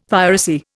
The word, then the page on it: piracy: Indian